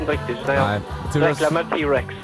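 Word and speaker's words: T. Rex: T-Rex.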